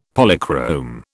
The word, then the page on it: Polychrome: British English